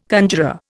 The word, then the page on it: conjuror: Indian